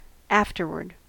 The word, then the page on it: afterward: American English